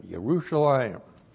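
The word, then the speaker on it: Yerushalayim (ירושלים): Y, Shiva, R, long U, S, H, A, L, I, M. Yerushalayim.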